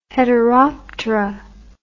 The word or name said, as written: heteroptera